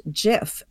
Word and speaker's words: GIF: But he very explicitly then and since then repeatedly has said that it's pronounced GIF, and they had in their office a slogan that choosy programmers choose GIF.